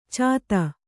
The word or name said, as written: ಛೇದಿ